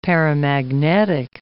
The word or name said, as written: paramagnetic